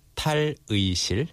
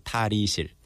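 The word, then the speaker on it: 탈의실: Also, yeah, tal-ui is supposed to be pronounced as 의 in the original pronunciation. – But people tend to say 이. – Tal-ui-shil. – They say 다리실.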